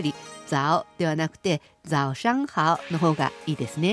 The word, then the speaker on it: 早上好: 早上好、早上好。 – これは早い遅いの早いという字、早朝の早という字に上、そして好きと書いて早上好と発音しますね。